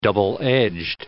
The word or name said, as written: double-edged